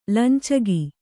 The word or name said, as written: ಲಂಚಗ